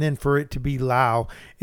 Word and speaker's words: Lowe: Another guy on the Rays, Brandon Lau. How many people do you know with the last name Lowe that's pronounced Lau? – Exactly 0.